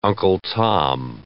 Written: Uncle Tom

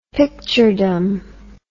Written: picturedom

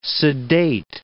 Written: sedate

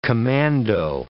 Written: commando

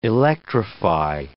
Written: electrify

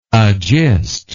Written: agistor

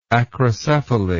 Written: acrocephalous

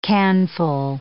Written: canful

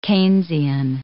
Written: Keynesian

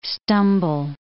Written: stumble

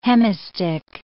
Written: hemistich

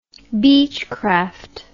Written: beechcraft

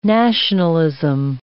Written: nationalism

